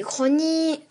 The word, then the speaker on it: Uchronie: Et Uchronie s'écrit U-C-H, qu'on prononce K, comme dans chorale. Donc U-C-H-R.